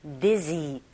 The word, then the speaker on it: dizzy: dizzy, dizzy, dizzy 아니고 dizzy, z, z.